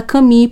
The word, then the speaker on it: Camus: O nome dele se escreve Albert Camus, mas se fala Camille porque ele é de origem francesa.